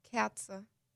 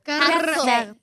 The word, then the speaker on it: Kerze: How do you say, like, candle? – Kerze. – Kerze. – A candle. – That's like Katzel. – Kerzel. Kerzel. Kerzel. – No. – That was like not even. – Netzel? – Kerze.